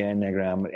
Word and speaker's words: enneagram: You have programs for them, but you talk about the an engram. I'm not going to say that right. And I've been that's enneagram. – One of that's it. You say it again. – Enneagram. – It's got too many E's in the wrong places.